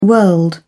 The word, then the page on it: world: British English